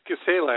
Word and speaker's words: Gisela: It's Gisela, actually, but a lot of people Gisela. – Call it Gisela.